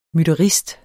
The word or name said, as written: MYTTERISTERNES